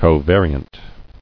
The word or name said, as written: Covariant